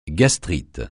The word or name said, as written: gastrite